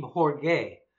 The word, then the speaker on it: Jorge: Yeah, no, it's well, first of all, it's called Team Jorge. It's the Spanish pronunciation, I think.